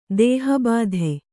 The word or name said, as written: ದೇಹಬಾಧೆ